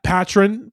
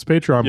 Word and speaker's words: Patreon: I'm trying to remember how patron. Patron is how my mom would try to pronounce Patreon. And it's like, nope, it's Patreon, mom.